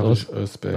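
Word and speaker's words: Barış Özbek: wie spricht man das korrekt Barisch Özbeck. Barisch Özbeck.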